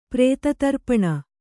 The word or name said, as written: ಪ್ರೇತತರ್ಪಣ